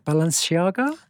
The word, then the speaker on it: Balenciaga: Balenciaga.